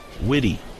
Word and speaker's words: Witte: Accent on the E at the end of his last name, W I T T E. Witty.